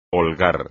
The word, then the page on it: holgar: espagnol